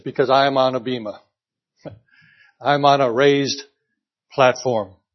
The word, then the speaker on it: Bema: It's spelled in English letters, B-E-M-A. We hear it Bema, but it's probably Bema.